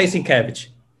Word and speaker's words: Sienkiewicz: I'll say Sienkiewicz.